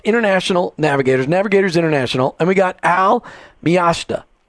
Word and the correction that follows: Miasta: Miasta. I said it wrong anyway, Miasta. even though I practice, even though I practiced, Miasta.